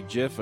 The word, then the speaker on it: GIF: I say GIF. It's supposed to be JIF according to the creator of the format.